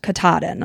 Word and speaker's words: Katahdin: They started at Mount Katahdin.